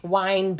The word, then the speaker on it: wine: I know I'm saying it wrong. I know it's bad. Y'all know how to wind it. – Yeah. – Wine, as you explain. – Mm-hmm. You don't need a D in there.